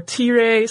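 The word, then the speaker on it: Thyra: And Gorm married a woman named Tira, or Tire, sometimes you'll hear it pronounced.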